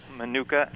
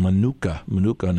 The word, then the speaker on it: Manuka: Manuka, M-A-N. – Okay. – U-K-A. – Manuka.